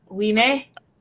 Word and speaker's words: Ouimet: How do you say it? It's oh. like spelled like it's spelled like O U I M E T. We may.